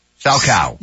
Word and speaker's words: Salchow: It's S-A-L-C-H-O-W, like sow chow. Sow cow.